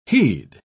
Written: heed

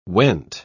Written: went

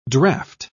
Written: draft

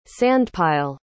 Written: sandpile

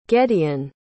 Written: gedeon

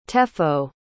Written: tefo

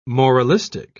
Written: moralistic

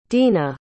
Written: drina

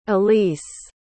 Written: ilyse

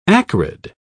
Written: acrid

